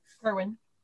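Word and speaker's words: Kirwan: And it tells the absolutely fascinating story of Dorothy Curran. Am I saying that correctly? Curran or? – Kerwin. But Kerwin. I'm Southern, so we often mess up every name.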